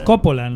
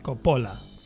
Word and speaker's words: Coppola: Es Coppola, ¿no? Bueno, no sé. – No lo sé. Yo no veo que lleve acento en la O primera. – Porque es italiano.